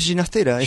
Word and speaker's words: Ginastera: ¿Cómo es el apellido entonces? – Es Ginastera, está bien.